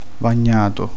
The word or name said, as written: bagnato